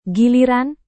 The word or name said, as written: giliran